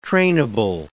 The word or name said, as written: trainable